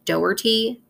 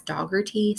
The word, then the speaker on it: Dougherty: Detective Chip Dougherty, I want to stop really quickly and say that it's spelled like Dougherty, but in the documentary Rough Cut, the only person who says his name says it as Dougherty, so that's what I'm going with.